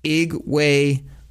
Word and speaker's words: Igwebuike: Next thing you know, Godwin, this is how you got to say it, four syllables, everybody. Godwin Igwebuike.